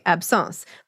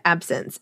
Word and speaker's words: absence: Out loud, you pronounce it the French way, absence. But when I see it in writing, I hear it in my head as the English word absence, as in being absent, which makes perfect sense to me because the way this seizure works, the person is there, but they're not there.